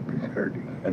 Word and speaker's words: Bizerte: B-E-S-E-R-T-E, Bizerte.